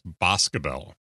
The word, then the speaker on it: Boscobel: That is actually pronounced Boscobel. – Ah, I had it. I had the emphasis on the wrong syllable.